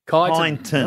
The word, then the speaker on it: Kyneton: Well, including the way you say it, because there are some, is there an N in there or not? Are we Kyneton or Kyneton? – Kyneton. – No, there's no N in there, they reckon. Well, the locals. – I'm going to spell it to you. – No, K-Y-N-E.